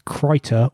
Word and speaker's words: Krita: Krita, this is Krita. This is the... do you keep correcting me? Am I saying it wrong every time? – Krita. – Krita. I'll get there eventually.